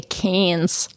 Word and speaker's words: Cairns: And they don't really pronounce their R's either. So like we say Cairns, but they would say Canes.